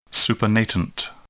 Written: supernatant

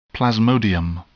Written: plasmodium

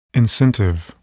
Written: incentive

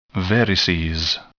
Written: varices

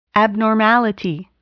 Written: abnormality

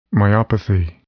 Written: myopathy

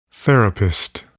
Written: therapist

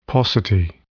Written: paucity

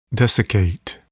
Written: desiccate

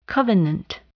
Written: covenant

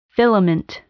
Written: filament

